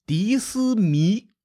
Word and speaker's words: 狄斯弥: 这个时候，鲁国又出现了一个猛将，叫做狄斯弥。嗨，听着像外国人是吧？这读音有点迪斯尼的意思啊！